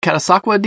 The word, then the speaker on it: Catasauqua: I got to send a big shout out to Pennsylvania and my Pennsylvania listener, Peter, who said I pronounced Catasauqua decently well.